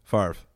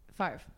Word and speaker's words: Favre: I actually don't know how to pronounce his last name. Brett Favre? – Favre. Have you ever seen something about Mary? Favre. – Favre. Really? – Yeah. – How big are his hands? – F-A-V-R-E.